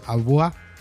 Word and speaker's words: Abua: El dúo que formaron se llama Abua, con B corta, Abua, parecido a agua, pero con B corta, Abua, con acento en la última.